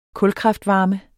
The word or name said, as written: kulkraftvarme